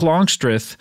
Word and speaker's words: Longstreth: So he and Jake Longstreth, actually, I believe is, he said, pronounce both syllables equally. Longstreth.